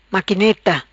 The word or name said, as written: Maquineta